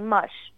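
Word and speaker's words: Mosh: Son nom s'écrit M-O-S-H. C'est moche.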